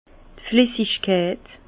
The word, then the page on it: d' Flessigkeit: Bas Rhin; Herrlisheim